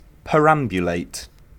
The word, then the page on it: perambulate: British English